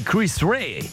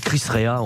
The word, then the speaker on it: Chris Rea: Et tenez, on parlait de tube de l'année 89 avec Cabrel, il y avait aussi Chris Rea. Alors on dit Chris Rea en France, mais c'est Chris Rea, la vraie prononciation.